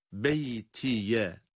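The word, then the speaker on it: بيتي: Evet, burada beytiye olarak yazılan kelimede duruşumuzu beyti diye gerçekleştiriyoruz.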